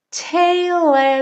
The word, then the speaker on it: tale: Tale as old as time. Tale, a gente não pronuncia a letra E, né? A gente termina no L.